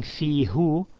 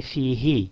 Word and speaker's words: fihi: instead of saying fihu, to say fihi.